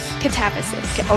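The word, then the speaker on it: Katabasis: And as we said before, she's got all these other great books, Babel and Necessity of Violence, Catabasis. I say it right? Catabasis? – Catabasis. – Okay. Okay, Catabasis.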